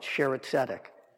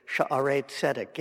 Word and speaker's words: Sha'arei Tzedek: Of course, the people who go there don't know how to pronounce it. They call it Sheretzedek. But Shira is song, and Sha'arei is gates. It's supposed to be Sha'arei Tzedek, gates of righteousness.